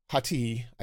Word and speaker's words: Hati: Sabinus came with Skoll and Hoti. Hati, I think is how they actually pronounce it in the show.